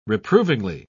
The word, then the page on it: reprovingly: British English